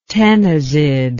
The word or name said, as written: ctenizid